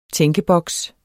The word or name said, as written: tænkeboks